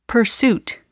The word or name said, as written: pursuit